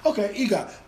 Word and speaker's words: EGOT: How do you pronounce it? EGOT, Emmy, Grammy, Oscar, Tony. EGOT. Okay, EGOT.